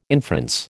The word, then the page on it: inference: American English